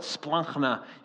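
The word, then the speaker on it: splankna (σπλάγχνα): S-P-L-A-G-K-N-A is roughly how you'd spell it, splankna. You got to kind of get it out of the throat there.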